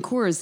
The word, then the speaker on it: Cors: Am I saying that correctly? – Yep, Coors. C-O-R-S.